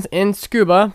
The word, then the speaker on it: Tsukuba: It's spelled T-S-U-K-U-B-A, but when you say it, it sounds like scuba diving, because the Japanese people speak really quickly and they tend to blend things together.